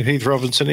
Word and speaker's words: Heath Robinson: which now make my all-too-frequent journeys to Heath Robinson International all the more bearable. Did I pronounce that correctly, sir? – Yeah, that's good. You know, Heath Robinson,